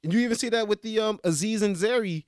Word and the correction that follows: Aziz Ansari: Did I pronounce his name right? Aziz and Zeri. – Aziz Ansari. What? Situation Aziz Ansari. Aziz Ansari.